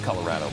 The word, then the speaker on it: Colorado: It's Colorado, by the way, not Colorado.